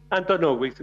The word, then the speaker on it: Antonovich: Antonovich, mira, cada uno lo pronuncia como quiere. Ya me acostumbré.